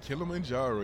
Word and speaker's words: Kilimanjaro: Kilimanjaro, yeah.